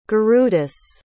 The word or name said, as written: gurudas